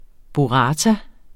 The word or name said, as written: burrata